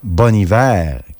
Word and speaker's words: Bon Iver: Un voyage qui s'est terminé ici avec Flume de Bon Iver, en fait Bon Hiver, qu'il faut prononcer,